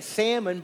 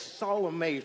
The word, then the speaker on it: Salome: So, her name, I always want to say Salmon, but it's Salome, something like that.